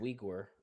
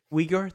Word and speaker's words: Uyghur: It's pronounced Uyghur. It's weird. – Uyghur? Thank you. I knew I was pronouncing it wrong.